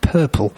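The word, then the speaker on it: Poeppel: And his name's not Purple the colour, it's P-O-E-P-P-E-L, but pronounced Purple, which I think is vaguely amusing.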